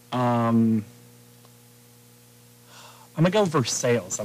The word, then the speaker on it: Versailles: Versailles, they won. – It's Versailles. – Versailles.